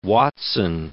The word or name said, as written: Watson